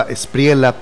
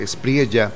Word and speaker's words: Espriella: Pensaba yo que se pronunciaba Espriella, pero él lo dice Espriella.